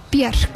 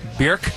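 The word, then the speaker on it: Björk: Are we saying it right, Bjork? – Bjork. – Bjork? Bjork. – Think of jerk, if someone's a real jerk.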